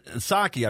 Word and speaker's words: Psaki: I saw Press Secretary Jen Psaki, I believe is how you say it, P-S-A-K-I, Jen Psaki.